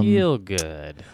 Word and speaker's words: Gielgud: Is that how you pronounce that? – Yeah, Gielgud.